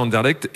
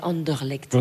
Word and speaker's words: Anderlecht: Mais j'avais essayé une sauce, une fois ils avaient sorti une sauce violette pour Anderlecht, en hommage à Anderlecht, et franchement, c'était pas terrible. – Ouais, excusez-moi, mais on dit Anderlecht.